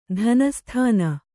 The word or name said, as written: ತನುಸ್ಥಾನ